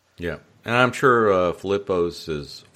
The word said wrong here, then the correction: Filippi's: did I say that right? Filippo's? – Filippi's, yeah.